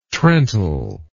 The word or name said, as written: trental